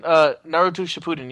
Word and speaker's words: Naruto Shippuden: Is it Naruto Shippuden? – Close enough. – Yes. – That was better than I think you've ever done. Naruto Shippuden, yeah.